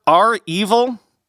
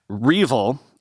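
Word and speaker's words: REvil: Remember R Evil? That big ransomware gang, by the way, I've learned it's pronounced R-Evil, not Reevil, as I was saying.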